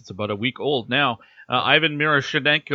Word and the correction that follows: Miroshnichenko: Oh, oh, I butchered that. Ivan Miroshchenko. I'm doing it again. This is a tricky name for me. – Miroshnichenko. – Thank you.